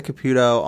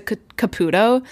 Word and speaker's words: Caputo: I also just want to clarify, like, in every other context I've ever seen her in, her name is pronounced Teresa Caputo. But on The View, Joy Behar was saying Caputo. – That's what I've been saying. – As an Italian... I just trust that Joy is pronouncing it correctly, and that for decades everyone else has been saying it wrong. So for the sake of this podcast, I'm going to also say Caputo.